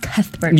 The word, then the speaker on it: Cuthbert: It's not like I don't know it, but it's just, you know, I'm just it's pronounced Cuthbert.